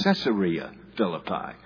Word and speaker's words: Caesarea Philippi: It's not Caesarea Philippi. It's Caesarea Philippi. Okay, folks, you can say Caesarea all you want to, but you're wrong. It's Caesarea. It was named for a Caesar.